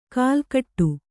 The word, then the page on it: ಕಲ್ಕಟ್ಟು: kalkaṭṭu